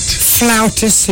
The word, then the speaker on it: flautist: Flautist. He plays a flute, but you don't call him a flutist. F-L-A-U-T-I-S-T. Isn't that pronounced? Flautist.